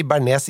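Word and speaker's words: béarnaise: Men jeg mener at eh, ord som bearnés Der skal man si bearnés, ikke Berné. – Ja. – Fordi Berné det er et forsøk på å snakke fransk, men mislykket.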